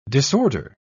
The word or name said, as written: disorder